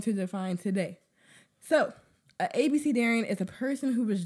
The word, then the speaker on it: abecedarian: So, at ABC Darian, let me spell it up for you. ABECEDARIAN. It's a noun.